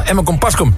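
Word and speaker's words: Emmer-Compascuum: Emme Kompascuum, zeggen wij altijd.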